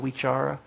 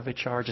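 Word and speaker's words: vicāra: They pronounce the V's as W's, vitaka vichara. I pronounce them as V's, vitaka vichara, just like vipassana.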